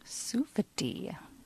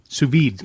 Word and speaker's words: sous-vide: It's called I'm not going to say this right, sous-vide. – Sous-vide. – Sous-vide. Thank you.